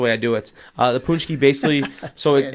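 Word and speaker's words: pączki: We say punchki. – Punchki. – Yeah, some people say patchki.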